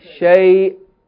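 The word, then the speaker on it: shay'un (شيءٌ): You say, Laysa kamithlihi shay'un. Stop. But you have to stop. Na shay. Stop. There's a stop there. That's Hamza. You have to pronounce the Hamza.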